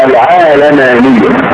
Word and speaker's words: العالمانية: هذه الكلمة النطق الصحيح لها أن تنطق العالمانية أو العالمانية فتح العين واللام، كما ان الكتابة الصحيحة لها تكون الوضع الف بعد العين، يعني العالمانية. هذا هو الامر الصحيح الذي يناسب حقيقة المعنى الذي نطلع عليه هذه الكلمة.